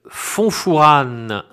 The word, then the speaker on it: Fontfourane: Vous pouvez également écrire pour commander ce livre à éditions Gros Texte Fonfourane. Ça s'écrit Fontfourane, 05 380 Châteauroux-les-Alpes.